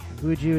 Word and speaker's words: BNXN: Buju is spelled BNXN, by the way.